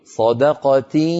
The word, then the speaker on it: صدقة: يا بنتي قولي صدقة، أنطقي في الوقت ذاته ياء من الفم وغنة من الأنف.